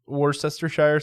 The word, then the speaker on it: Worcestershire: So most Bloody Mary mixes are made with Worcestershire sauce. – Ooh, you said that right the first time.